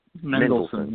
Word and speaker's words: Mendelsohn: Mendelsohn, yeah.